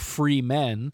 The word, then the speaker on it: Fremen: And my other problem is they call them Fremens. I realize it's spelled Fremen, but I thought it was just a play on that they were free men. And in the book, when I read it, I called them Freemen.